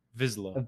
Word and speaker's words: Vizsla: Just to make sure I'm saying it correctly, is it Vizsla or Isla? – Vizsla.